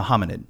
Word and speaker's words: hominid: So define for me, how do you pronounce it? Hominoid? – A hominid. – Hominid, excuse me.